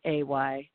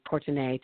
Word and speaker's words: Courtenay: My Twitter account is at Courtney Taylor, and my name is spelled Courtenay, fancy, Courtenay Taylor.